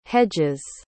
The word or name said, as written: hedges